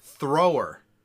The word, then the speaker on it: Thrower: Willie Thrower, and yes, I'm saying that correctly.